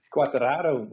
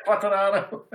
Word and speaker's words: Quartararo: Hoe spreek je zijn achternaam uit? – Quartararo, maar goed. Ik ben... – Oké? – Ik ben ook met een zeggen, Quartararo. – Quartararo, oké.